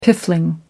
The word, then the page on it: piffling: American English